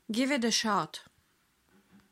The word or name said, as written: give it a shot